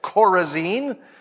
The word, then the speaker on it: Chorazin: Chorazin is Chorazin.